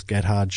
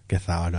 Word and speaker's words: Gethard: They do Adam Ruins Everything, Carbonaro Effect, Chris Gethard Show. Gethard, I should say.